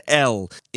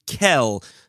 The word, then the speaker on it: El: the name of God that is spelled Aleph Lamed, we would pronounce in prayer and in formal enunciations of the pasuk, of the verse, would be pronounced El. If we're in a less formal space, so then many would use Kel, so as not to say a full name of God in vain.